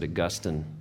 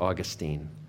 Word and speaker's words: Augustine: I pronounce it Augustine, because that's the way I learned it. But people also pronounce it Augustine.